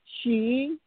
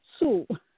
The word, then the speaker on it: Hsu: Last name is H-S-U. – So B-O-C-N-G? – B-O-C-H-E-N-G. – Oh, okay. – Like Henry. So Borton, she, last name is H-S-U. – Okay. – I know a lot of Americans, pronounce Sue. We pronounce she.